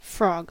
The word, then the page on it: cœur: France; [lø kœʁ]